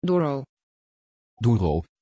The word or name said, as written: Doro